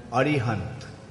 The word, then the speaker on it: Arihant: Actually, they pronounce his name as Arihant.